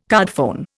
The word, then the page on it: cardphone: Indian